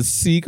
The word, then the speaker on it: Sikh: It's pronounced Sikh, by the way.